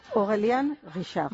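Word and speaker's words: Aurélien Guichard: el maestro perfumista Orelin Ogelin Guichard, no sé si lo he dicho bien, Lola. Aurelien Guichard. – Muy bien, mucho mejor.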